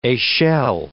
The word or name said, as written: echelle